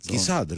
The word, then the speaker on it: Κεσάτ: Κεσάτ, σωστά τον είπες. Έφυγε. – Μα έτσι προφέρεται.